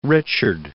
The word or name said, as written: Richard